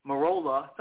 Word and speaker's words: Marola: and he says it's pronounced like the car Corolla, so I'll say Marola.